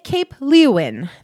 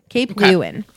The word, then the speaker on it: Leeuwin: you get Cape Leeuwin. That's spelled L-E-E-U-W-I-N. Cape Leeuwin.